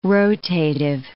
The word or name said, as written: rotative